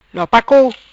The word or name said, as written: Lo Pac Cou